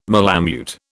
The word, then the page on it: malamute: British English